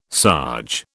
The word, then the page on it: sarge: British English